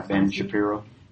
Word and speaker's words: Ben Shapiro: There's an article in the current issue of World Magazine by, they had an interview with a young man named Ben Shapiro, I don't know how he pronounced it. – Yeah, Ben Shapiro.